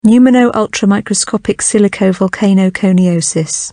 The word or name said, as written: pneumonoultramicroscopicsilicovolcanoconiosis